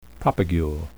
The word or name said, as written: propagule